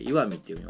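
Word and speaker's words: 石見: 石を見るって書いて岩見って読みますが、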